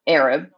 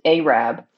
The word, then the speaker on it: Arab: And it's spelled Arab, but you pronounce it Arab.